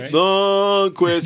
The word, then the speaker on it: Lundqvist: It's like the third goal, and then it's like, Lundqvist. You got to do it like a two-syllable. – Okay, all right. – Lundqvist.